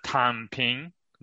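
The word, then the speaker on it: Tang Ping (躺平): How do you say that properly? – Tang Ping. That means lying flat, basically.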